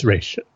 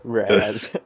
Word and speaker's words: Ra's: Yes, it's Ra's. I will forever only call him Ra's al Ghul. – I'm forever calling him... What did that lady say? – Raz. You got to say it like she does.